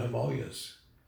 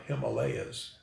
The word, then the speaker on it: Himalaya: What if you go out in the mountains or out in the desert or up in the Himalayas? Commonly pronounced in America, Himalayas, but it's actually pronounced Himalaya.